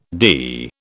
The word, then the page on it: DE: British English